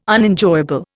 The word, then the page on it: Unenjoyable: Indian